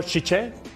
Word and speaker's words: Tite: Neymar los tenía puestos en semifinales con ese gol y ahí Tite tenía Chiche, para que lo pronuncie yo bien. Correcto.